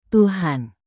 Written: tuhan

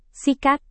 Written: sikat

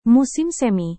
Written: musim semi

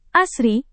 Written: asri

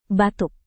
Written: batuk

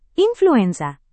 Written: influenza

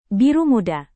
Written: biru muda

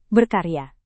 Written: berkarya

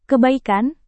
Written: kebaikan

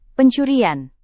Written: pencurian

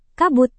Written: kabut

